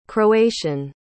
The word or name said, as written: croatian